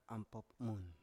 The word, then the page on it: ampop mun: Biak